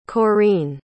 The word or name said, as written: Coreen